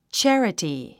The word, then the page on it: charity: tʃǽrəti; チャラティ